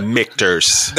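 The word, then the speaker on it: Michter's: Gotta learn how to say it. The Michters. – The best thing that guy when we went on the tour, the guy said it's like Victor's. Yeah. With an M, I was like, yo, all right, I'll never forget it.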